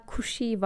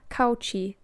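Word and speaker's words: Cauchy: Mesela Kushi var ama şey diye yazılıyor. Yani İngilizce okuyacak olsam kauchi derdim ama Kushi diye okunuyor.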